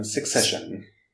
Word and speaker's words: Succession: Success und dann Ion, Succession. – Also von Sick. – Succession. Sex. Succession von erfolgreich. Also Succession.